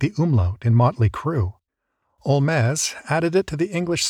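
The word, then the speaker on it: Holmès: In any event, it looks like English, is pronounced like French, and sounds vaguely like Spanish, Olmez.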